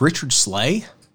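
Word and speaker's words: Richard Sligh: It's spelled like S. – Like Slay Bell? – Like S L I G H.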